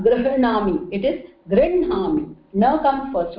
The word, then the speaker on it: गृह्णामि: All these are anunasika swaras, and wherever they come with a, they are pronounced first, although her is written first. Okay, so grinhami.